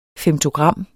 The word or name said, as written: femtogram